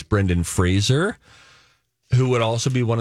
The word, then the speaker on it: Fraser: But Brendan Fraser wants everyone to know that his last name is pronounced Fraser, mm-hmm. – like razor, Rise of Lights. – Brendan Fraser. – So like laser, say Fraser.